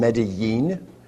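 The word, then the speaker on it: Medellín: I learned that if you're down if you live in Medellin, you pronounce it Medellin. And so you can tell a local from somebody from Bogota.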